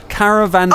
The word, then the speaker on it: Caravanserai: Caravanserai.